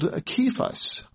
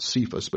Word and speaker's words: Kephas: You shall be called Kephas," as it would have been pronounced in Hebrew, not Cephas, but Kephas, which is translated a stone.